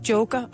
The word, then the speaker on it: Jokar: Undskyld, øh, hvordan udtaler jeg dit efternavn? Siger bare Joker.